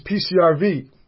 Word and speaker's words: PCRV: So you got to hit me with this PCRV. It's an acronym, or am I pronouncing it wrong? – Nope, you're right.